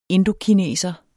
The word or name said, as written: indokineser